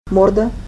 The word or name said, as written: morda